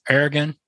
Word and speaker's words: Ergon: He somehow, some way, trying to appeal, I guess, to more markets, gets involved with the Ergon brand. Am I saying that correctly? – Ergon. Yeah. Either way.